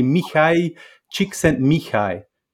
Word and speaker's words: Mihai Csikszentmihalyi: But it's actually Mihai Csikszentmihalyi.